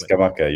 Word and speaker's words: Scamacca: I've said Scamacca. People in our live chat boxes have criticised me and said it's Scamacca. Can you give me some help? How am I pronouncing this correctly? – It's Scamacca. You're right.